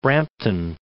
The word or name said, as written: Brampton